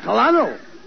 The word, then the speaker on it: Colonel: Colonel. You dummy, that Colano, that's a pretty high rank. – No, not Colano. – It is. – No, you see, that word is pronounced Colonel. – Colonel? – Yes, Colonel.